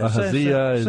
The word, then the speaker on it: Ahaziah: Ahaziah or okay. Ahaziah.